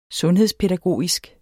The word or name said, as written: sundhedspædagogisk